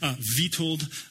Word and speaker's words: Witold: I read a story recently about a guy named Vitold Pilecki. It's spelled with a W, but because I know how to speak Russian, I don't.